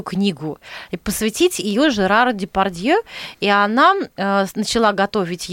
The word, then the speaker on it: Депардье: Депардье. – Она Депардье правильно да, произносит.